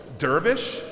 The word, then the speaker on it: dervish: Dervish? Is that how you say it? Whirling what? – Dervish.